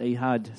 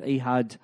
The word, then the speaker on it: Ehud: I was going with Ehud, but she said, no, it's Ehud. So we're going with Ehud this morning.